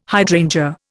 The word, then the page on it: hydrangea: Indian